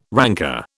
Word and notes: ranker: British English

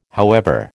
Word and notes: however: American English

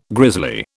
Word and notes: grisly: British English

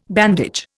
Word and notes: bandage: Indian

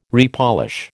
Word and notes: repolish: American English

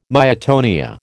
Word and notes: myotonia: American English

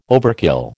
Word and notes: overkill: American English

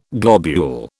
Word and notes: globule: British English

